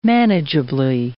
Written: manageably

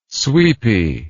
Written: sweepy

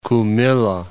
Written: Kumilla

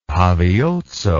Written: paviotso

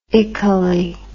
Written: ically

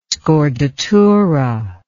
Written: scordatura

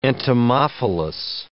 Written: entomophilous

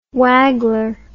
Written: waggler

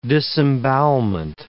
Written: disembowelment

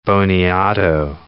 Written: boniato

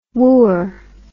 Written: wooer

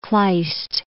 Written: Kleist

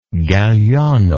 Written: Galliano

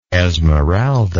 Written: Esmeralda

